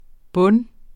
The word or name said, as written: bunde